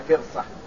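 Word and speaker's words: فِرصة: فرصة من مسك يعني الفرصة، قال الشيخ يعني وهذا معلوم في اللغة بكسر الفاء وسكان الراء وبالصاد المهملة، يعني فرصة هكذا نطقها، القطعة من كل شيء.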